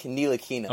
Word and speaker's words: Ntilikina: Frank Ntilikina.